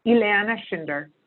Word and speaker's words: Ileana Schinder: Ileana Schinder.